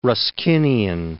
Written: Ruskinian

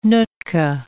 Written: Nootka

